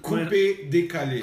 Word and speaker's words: coupé décalé: But we heard it and we're like, holy shit, this is like proto coupé décalé from the 70s, like the roots of it all. – How do you say that again? Because I'm coupé décalé.